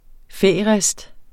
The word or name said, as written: færist